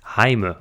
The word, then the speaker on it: Jaime: Für alle, die es eigentlich als Jamie aussprechen, tatsächlich englisch ausgesprochen ist es Jamie, aber in Wirklichkeit heißt es Heime, so wird es ausgesprochen.